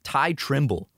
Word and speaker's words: Tyghe: His name is Ty, but it's spelled T-Y-G-H-E. Ty Trimble.